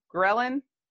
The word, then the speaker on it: ghrelin: Ghrelin? – Ghrelin, yeah.